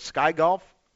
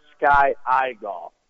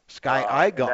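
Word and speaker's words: SkyiGolf: Now, Sky Golf, first question, it's spelled capital S-K-Y, small I, capital G-O-L-F. Is it? Are we pronouncing it right? Is it Sky Golf? – It's actually pronounced Sky Eye Golf. – Sky Eye Golf.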